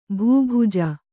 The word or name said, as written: ಭೂಭುಜ